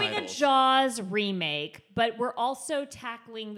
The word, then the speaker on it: Jalz: You have a show, and you have to pronounce it for me because I've only seen it spelled out. It's J-A-L-Z. – Jaws. – Jaws. – Jaws, yeah.